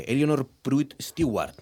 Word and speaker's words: Eleanor Pruitt Stewart: Daniel, Laura, teníamos muchas ganas de charlar con vosotros para que nos contéis la vida de Eleanor Pruitt Stewart. ¿Se pronuncia así? – Sí. – Así, tal cual.